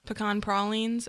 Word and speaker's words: pecan pralines: And I will, you know, full confession, I tried to make pecan pralines, and that is the correct way to pronounce that.